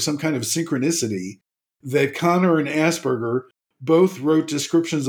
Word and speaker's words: Kanner: It looks like Canner, but it's pronounced Connor.